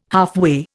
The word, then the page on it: halfway: Indian